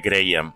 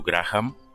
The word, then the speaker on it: Graham: Sí, de fet es pronuncia Graham, malgrat tothom diu Graham, però eh, correctament es diu Graham.